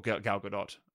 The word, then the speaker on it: Gal Gadot: I think he gets the genre, and I think that these classics, I love the fact that he's got Armie Hammer and Gal Gadot, or Gal Gadot, I think is the actual way to pronounce it.